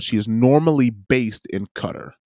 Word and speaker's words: Qatar: Qatar is how you pronounce it. That's what Fareed Zakaria says.